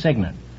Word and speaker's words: cygnet: Gosling. – Oh, Gosling. You don't know Guest. – Gosling. – No, it's Signet. It's spelled C-Y-G-N-E-T, but it's pronounced Signet.